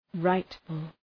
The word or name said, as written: rightful